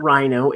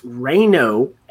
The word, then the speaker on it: Ryno: But the signings this week, it's not Rhino, it's Reino Eckstein, although it is spelled R-Y-N-O.